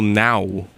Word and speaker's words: Nau: That was a root by Michael Now. I think that's how his last name is pronounced. N-A-U.